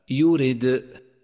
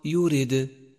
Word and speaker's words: يورد: رابعا، لاحظ صفة القلقلة لحرف الدال في كلمة يورد، يورد، وقد لون بالأزرق الفاتح للدلالة على هذه الصفة، ولكن احذر عند نطقه من أن تتبعه بهمز، أي هكذا يورد، يورد، والصواب يورد، يورد، وحروف القلقلة يجمعها قولك قطب جد.